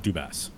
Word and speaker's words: Dubas: I believe it's pronounced Dubas.